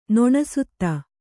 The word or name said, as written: ನೊಣಸುತ್ತ